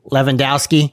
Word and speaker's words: Lewandowski: Then you got Lewandowski. It's pronounced differently than it's spelled for most of us. If you want to look it up, it's L E W A N D O W S K I.